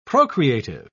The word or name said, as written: procreative